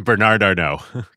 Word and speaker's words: Bernard Arnault: Bernard Arnault.